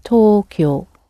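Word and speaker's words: Tokyo (東京): In Japanese, it's pronounced Tokyo.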